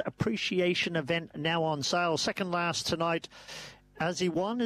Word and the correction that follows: As He Owned: am I saying that right, on top? – As he owned.